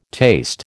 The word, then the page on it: taste: American English